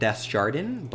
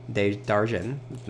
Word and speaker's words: Desjardins: I was pronouncing it Desjardins, but now I know the French pronunciation, Desjardins. Okay, maybe I can't perfectly do it.